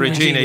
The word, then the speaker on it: Regina: Regina, yes. We chose the simplest pronunciation. – Several pronunciations for that in different productions.